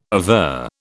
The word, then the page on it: aver: British English